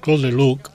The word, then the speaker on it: Claude Lelouch: Claude Lelouch.